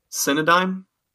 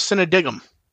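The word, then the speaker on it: Cinedigm: But doing great on the mispronunciations today. – What I mispronounced that time? – Cynodyme? – I thought it was Cynodigum. – I have to check on that. Anyway. – Well, okay, if it is Cynodyme,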